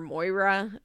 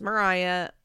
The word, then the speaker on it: Moriah: So Ethan, Hosanna, Micah, Moriah, who it's spelled with an O. I always want to call her Moira, um, but it's Moriah, Lydia, Isaac, Amber, Cassia, and Mercy.